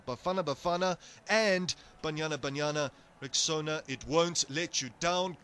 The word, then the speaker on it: Rexona: A friend called it Rexona, but guys, it's Rexona, like Rexona, Rexona.